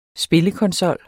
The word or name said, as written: spillekonsol